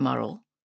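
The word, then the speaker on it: Morrell: Reading some of the tributes sent to her husband, Philip Murrell, usually pronounced to rhyme with Durrell, after her death in 1938, I encountered a different person.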